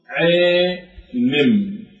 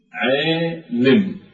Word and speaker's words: عالم: أجعل حركة بين الكسر والفتح، فأنطقها على هذا النحو عالم عالم. فهذا النطق كلمة عالم لا هي عالم ولا هي عالم، لا إلى الفتح ولا إلى الكسر.